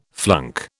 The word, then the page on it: flunk: British English